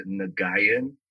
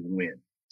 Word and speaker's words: Nguyen: When I was going through school, I actually said Nguyen, like really, just really spelt it out. But the proper way to say it is actually Nguyen. So the N-Z is actually, yeah, it's actually silent. – That's right. – So the U-Y-E-N is Nguyen.